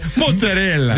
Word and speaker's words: mozzarella: Mozzarella. – They don't even say the A, they go mozzarella.